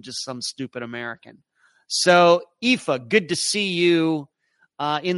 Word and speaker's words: Aoife: Ayoif. Hello once again from Edmonton. It's always so enjoyable listening to you. Oh, there we go. It's pronounced Aoife. Pronounced Colleen. Aoife, Colleen. Both Irish names meaning beautiful daughter.